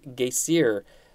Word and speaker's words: Geysir: The first geyser or the first named geyser is in Iceland, and it's pronounced, I think, geysir, spelled G-E-Y-S-I-R.